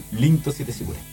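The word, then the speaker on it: Rinto Siete Sigure (凛として時雨): Rinto Siete Sigure. Se pronuncia en español Rinto Siete Sigure.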